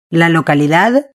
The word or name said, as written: la localidad